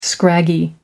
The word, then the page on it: scraggy: American English